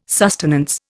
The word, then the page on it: sustenance: Indian